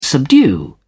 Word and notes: subdue: British English

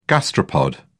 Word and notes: gastropod: British English

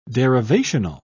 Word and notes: derivational: British English

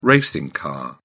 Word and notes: racing car: British English

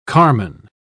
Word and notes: carman: British English

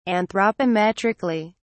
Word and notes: anthropometrically: British English

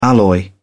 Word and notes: alloy: British English

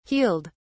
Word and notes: healed: British English

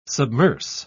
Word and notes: submerse: British English

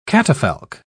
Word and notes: catafalque: British English

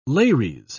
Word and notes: lares: British English

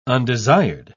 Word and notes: undesired: British English

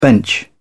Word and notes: bench: British English

